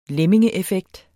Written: lemmingeeffekt

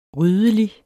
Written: ryddelig

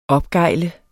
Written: opgejle

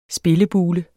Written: spillebule

